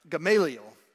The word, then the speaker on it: Gamaliel: Gamaliel.